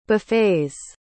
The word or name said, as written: buffets